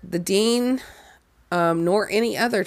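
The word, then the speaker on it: Dene: Like you said, the pronunciation is Dean, right? – Yeah, that's what I saw.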